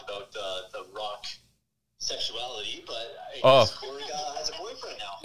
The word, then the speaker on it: Korg: Is it Korg? – Yeah.